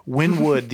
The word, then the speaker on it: Wynwood: It's kind of like a neighborhood outside of Miami called Wyndham or Wynwood. – Wynwood, the entire neighborhood is just – thank you for fact checking. It was one fucking syllable, all right? But it's called Wynwood.